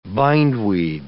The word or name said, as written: bindweed